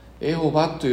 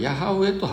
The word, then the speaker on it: ヤハウェ: 文語訳の聖書はエホバという言葉が書いてありますけれども、これは間違った翻訳の発音で、エホバというのは本来どこにもないんですね。これは本来ヤハウェと発音すべきなんです。